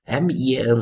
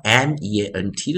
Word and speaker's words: meant: 就像我们讲 m e a n，我们是念 mean，那 m e a n t 的时候，我们是念 meant。